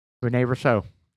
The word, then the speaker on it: Rene Russo: Reno Russo? How do you say the name of Russo? – Rene Russo.